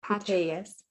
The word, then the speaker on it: Putellas: So I was reading earlier today that the Barcelona captain and the Spain national team captains of the women's side, Alexia Putellas? – Putellas.